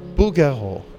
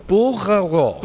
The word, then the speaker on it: Bouguereau: Bouguereau. – Bouguereau. – Bouguereau. Absolument. C'est parfait. Bouguereau.